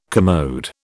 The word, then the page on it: commode: British English